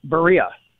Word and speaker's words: Berea: I'm so glad you asked me that because I've been saying the name wrong, apparently, and someone corrected me. It's Berea. I'm in Berea, Ohio, home of the Browns training facility.